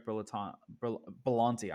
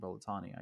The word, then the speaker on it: Berlanti: they're going to Greg Berlanti. I keep saying Berlatani. I keep saying his name wrong.